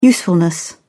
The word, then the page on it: usefulness: British English